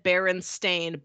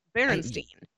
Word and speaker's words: Berenstain: I remember very distinctly the book said Berenstain, but my mom pronounced it Berenstain. Berenstein.